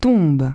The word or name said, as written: tombent